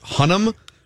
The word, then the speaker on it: Hunnam: Is it pronounced Hunnam? – Yeah, from Sons of Anarchy.